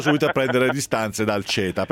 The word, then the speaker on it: CETA: Diciamo che si potrebbe pronunciare CETA, ma noi italiani lo pronunciamo CETA.